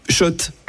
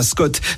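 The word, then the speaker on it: Schott: Shot, oui. Et pas Scott. Ça fait des années qu'on prononce mal la marque.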